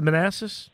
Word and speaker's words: Manassas: I think it's Manassas. Is it Manassas? Yes, okay.